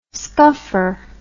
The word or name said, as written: scuffer